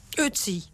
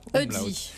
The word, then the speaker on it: Ötzi: Ötzi. – Ötzi. Faire attention aux prononciations. Il y a un tréma sur le haut, c'est ça? – Un umlaut. – Ah oui, Ötzi.